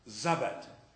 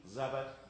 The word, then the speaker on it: Zabat: One evening, this is Pastor Zabat. You say it like Sabbath, but it starts with a Z, Zabat.